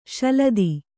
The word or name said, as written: ಶಲದಿ